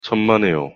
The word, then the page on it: 천만에요: Korean